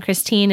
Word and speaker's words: Christine: And my name is spelled L-A-U-R-I-E. And then Christine is with a C-H, C-H-R-I-S-T-I-N-E.